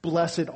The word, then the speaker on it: blessed: they start out saying, blessed are, instead of blessed are. And so I, I looked up the word to see, you know, is blessed, is that a proper pronunciation of this? Sounds like a King James kind of word to me, blessed, instead of saying blessed.